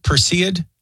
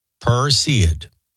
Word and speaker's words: Perseid: Am I saying that right? Perseid? I believe so. Perseid.